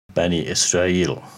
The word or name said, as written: Bani Israil